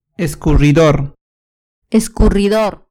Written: escurridor

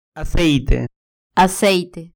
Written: aceite

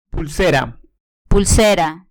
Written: pulsera

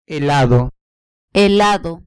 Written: helado

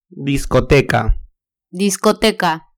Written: discoteca

